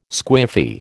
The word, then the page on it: squiffy: American English